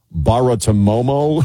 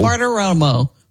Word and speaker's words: Bartiromo: Baratomomo, Romo, Baratomomo. – Bartiromo.